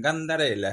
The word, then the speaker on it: Gandarela: Se llama Gustavo, dime tú si lo digo mal, Gandarela, ¿no? Correcto, correcto, correcto.